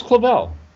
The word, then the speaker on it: Clavell: That was Clavell. – Clavel. – Was that how you pronounced it? – Yeah, made a fortune.